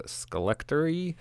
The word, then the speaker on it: Skelectory: Next band is called Skelectory, and the name of the song is Lucid. That was the song Lucid by the band Skelectory.